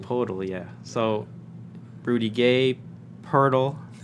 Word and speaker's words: Poeltl: Jakob Poeltl. Am I saying that right? – Nailed it. It's like turtle but with a P.